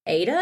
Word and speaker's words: Ada: In a small town called Ada. Is that how you say it? Good job.